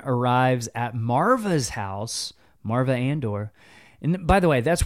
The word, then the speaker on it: Andor: His name was just Cassa, and then he changed it to Cassian Andor. Andor.